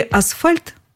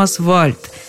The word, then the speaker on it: асфальт: В слове «асфальт» букву «Ф» на «В» обычно заменяют дети – Почему? Потому что так удобнее произносить. Асфальт сказать гораздо труднее, чем асфальт. Нужно некоторое усилие. И только потом, когда ребенка несколько раз поправили, он начинает говорить правильно. Но ведь это дети.